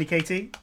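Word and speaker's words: Caity: And amazing thank you to our very special guest, Tiny Katie.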